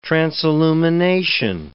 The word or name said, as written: transillumination